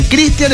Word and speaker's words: Cristian: Cristian es cuando es CH, porque es gringo, y Cristian, como me llamo yo, es porque es nombre español.